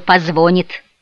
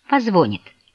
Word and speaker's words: позвонит: Почему ты говоришь «позвонит»?» — спросила Шарлотта. «Разве ты не знаешь, что в этом глаголе ударение ставится на последнем слоге?» По крайней мере, так все грамотные люди говорят.